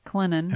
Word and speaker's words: Clinton: the T is sweet and the T is silent. – Yep. – Clinton.